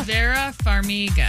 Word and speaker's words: Vera Farmiga: Vera? – Vera Farmiga. – Farmiga. – Yep.